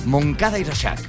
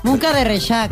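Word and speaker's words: Moncada i Reixac: Bueno, nos vamos a Moncada y Reixac. – Moncada y Reixac, ¿lo he dicho bien? Sí.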